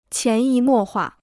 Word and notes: 潜移默化: qián yí mò huà

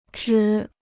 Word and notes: 值: zhí

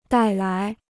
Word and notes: 带来: dài lái

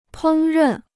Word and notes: 烹饪: pēng rèn

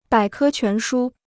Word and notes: 百科全书: bǎi kē quán shū

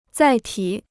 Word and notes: 载体: zài tǐ

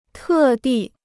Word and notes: 特地: tè dì

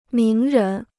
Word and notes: 名人: míng rén

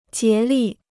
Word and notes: 竭力: jié lì

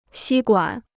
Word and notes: 吸管: xī guǎn

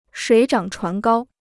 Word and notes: 水涨船高: shuǐ zhǎng chuán gāo

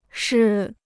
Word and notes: 式: shì

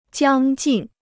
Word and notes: 将近: jiāng jìn

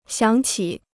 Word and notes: 想起: xiǎng qǐ

